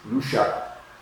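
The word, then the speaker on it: Lúcia: I learned how to say their names in Portuguese. Lucia, Francisco, and Jacinta. We always say Lucia. Lucia, Francisco, and Jacinta. But no, it's in Portuguese. Lucia, Francisco, Jacinta. Anyway,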